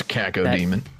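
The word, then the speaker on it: Cacodemon: the Caco demon. I don't know if that's how you say it, but it's Caco demon.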